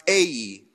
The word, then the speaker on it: Ai: Ai, it's pronounced Ai.